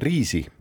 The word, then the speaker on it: Rysy: Poola kõrgem tipp kannab nime Riisi, kirjutatakse Y-itega, et R-Y SY. riisi hääldatakse ja